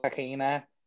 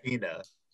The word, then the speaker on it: tahina: Yeah, a little tahini, a little tahina. – Tahina, yeah, that's how you say it. – Tahina, yeah. – Tahina. That's how Persians say it.